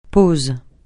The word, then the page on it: pause: francais